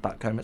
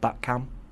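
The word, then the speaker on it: Butcombe: And, you know, said that to a guy the other day, and he said it's not pronounced Buttcomb, it's pronounced Buttcum.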